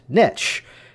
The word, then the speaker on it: niche: It's a familiar phrase that the word that's spelled N-I-C-H-E, is it pronounced niche or is it pronounced niche? And really, the preference is the word niche because it rhymes with rich.